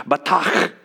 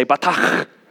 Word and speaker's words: batach: the Hebrew word for trust was batach. So can you guys say batach?